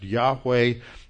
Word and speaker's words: Yahweh: Y-H-W-H, usually pronounced Yahweh, mistranslated many centuries ago as Jehovah. The way it's written in Hebrew, there are no vowels. So all you have is a Y-H-W-H. The Y is often transliterated into English as a J, so that's where you get the J. The W is usually translated as a V. In fact, in Hebrew, the W is often pronounced as a V, and so that's where you get the J and the V, the J-H-V-H.